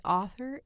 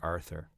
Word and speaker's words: author: By the way, author is one of the words that I always have to... put through my mind. – Because otherwise you'll say Arthur. – Well, because I think I'm saying it wrong because of being a Bostonian.